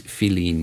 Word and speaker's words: filín: And it's, you know, in Cuba, they spell it F-I-L-I-N, which is the way it sounds in Spanish, or how it's spelled in Spanish.